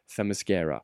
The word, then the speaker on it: Themyscira: det är svårt att uttala, Themyscira. Inte them, många tror att det är, jaha, så det, det är mis, mascara. mascara. – Det har ingenting med mascara att göra.